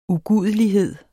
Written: UGUDELIGHED

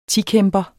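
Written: tikæmper

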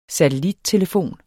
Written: satellittelefon

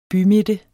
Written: bymidte

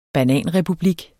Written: bananrepublik

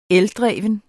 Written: ELDREVNE

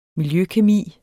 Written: miljøkemi

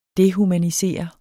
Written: dehumanisere

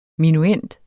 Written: minuend

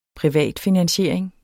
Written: Privatfinansiering

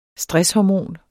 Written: STRESSHORMONET

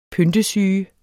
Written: PYNTESYGE

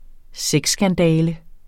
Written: sexskandale

